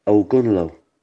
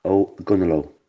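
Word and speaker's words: Ogonnelloe: Hi, it's pronounced O'Gunlow. O'Gunlow.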